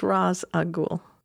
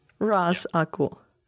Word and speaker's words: Ra's al Ghul: No, it has to be Ra's, right? Because that's how it's pronounced. Please don't pronounce it any other way. It's Ra's al Ghul.